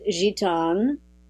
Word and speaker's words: Gitane: It's interesting that, for example, my name is Gitane, and with an A pronounced A,